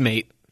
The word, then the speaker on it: Mate: The only reason why I know it is because I've had that same, like, is it Christian mate or is it mate? I'm assuming it's Croatian and there's some pronunciation that we're missing in there. – Nah, it's just mate.